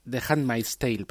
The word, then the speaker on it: The Handmaid's Tale: Pues eh, The, Handman, The, Handman's, The Handmaid's Tale, perdón, porque la pronunciación ahí me ha salido mal.